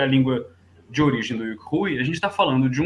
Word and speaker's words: Yuk Hui: Então, Yuk Hui, que a gente fala, é a pronúncia do nome dele em cantonês.